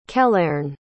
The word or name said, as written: kelairn